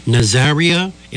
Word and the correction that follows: Nazaire: and Raoul? – Yeah, Nazaire and Raoul were two brothers.